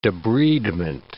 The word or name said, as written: debridement